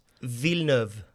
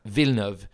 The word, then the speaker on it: Villeneuve: Villeneuve. Villeneuve.